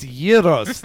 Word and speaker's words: gyros: It's gyros. The G is silent.